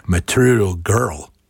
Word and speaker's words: Material Girl: To je asi správnější výslovnost. Material Girl. OK, tohle už šlo.